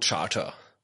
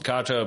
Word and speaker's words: Charta: Everyone says code Charter. – Oh, okay. All right. – I don't know. Someone came up with it. Everyone pronounced it wrong. that's why it's a good name. and yeah. I think it's Latin or so, or Greek, yeah, I don't know. Yeah. And mm-hmm. apparently that's how you're supposed to pronounce it, Carter, but it's spelled kind of Charter.